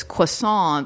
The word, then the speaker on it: croissant: She says, that Beth Bear's character goes, "Oh, it's croissant. The T is silent."